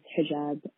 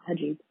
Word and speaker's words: hijab: It is hijab and not hijab, at least in Arabic.